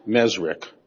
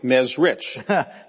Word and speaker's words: Mezrich: Now, your last name is pronounced Mezrich, but it's spelled R-I-C-H. – Yeah. – Why don't you pronounce it Mezrich?